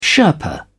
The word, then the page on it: sherpa: British English